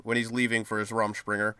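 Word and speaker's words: Rumspringa: And we find out that Jacob is on Rumspringer. Is that how you say it? – Rumspringer, yeah.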